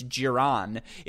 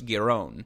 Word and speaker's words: Giron: You would have learned that it's not Marcos Giron, it's Marcos Giron.